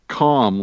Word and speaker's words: calm: Oh yes, it's a homophone with calm, like dot com. You don't pronounce the L.